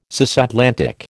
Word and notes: cisatlantic: American English

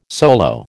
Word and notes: solo: American English

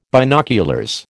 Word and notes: binoculars: American English

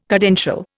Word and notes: cadential: Indian

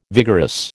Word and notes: vigorous: American English

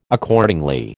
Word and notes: Accordingly: American English

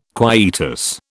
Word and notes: quietus: British English

